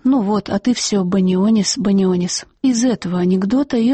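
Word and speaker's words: Banionis: Народный артист Донатас Банионис, по-литовски фамилия произносится Банионис, в советские годы был настолько популярным, что становился и героем фольклора, в смысле анекдотов.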